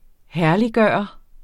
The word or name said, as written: herlig